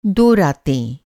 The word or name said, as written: रात